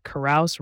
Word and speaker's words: carouse: One way to remember this word is that carouse rhymes with house, and you might typically carouse at a house party.